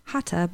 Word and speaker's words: Hatha: So I'm talking about my class, which the type of yoga that I teach is Hatha, pronounced Hatha, but spelled H-A-T-H-A. And you know what? For the majority of my life, mm-hmm. I said it Hatha.